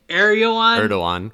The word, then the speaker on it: Erdogan: Erdogan.